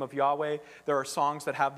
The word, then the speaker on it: Yahweh: And so in ancient Hebrew, it was YHWH, likely pronounced Yahweh. And so here, when you see L-O-R-D in capitals, and I checked the Hebrew just to make sure I wasn't teaching falsely this morning.